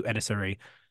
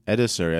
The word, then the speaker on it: Edassery: Edassery, I feel like you pronounced that differently before. – I think I pronounced it correctly this time.